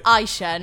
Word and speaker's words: Ayesha: It's Aisha, not Aisha, Aisha, but it's fine.